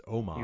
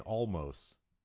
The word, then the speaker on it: Omos: I've been mispronouncing his name this whole time and no one corrected me. It's Omos. – You have? – Omos. – I've been saying almost.